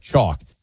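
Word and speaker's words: Choq: Chalk. That's C-H-O-Q spelled with a Q.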